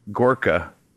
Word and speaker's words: Gorka: Your most recent article was about, make sure I get his name right, Gorka. Is that how he pronounce it? – To the best of my knowledge, that's how he pronounces it.